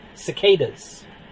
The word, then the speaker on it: cicadas: The kikadas, the sikadas? What are they called? How do you pronounce that word? Cicadas.